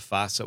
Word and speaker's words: Vasa: And then before or after that, it was a team, the Vasa. What was their name in Finland? – Yeah. So it's Vasa. – Vasa Royals. – The Vasa Royals. – Yeah. The W is pronounced as a V.